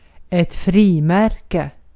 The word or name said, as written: posta pulu